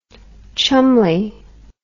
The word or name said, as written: cholmondely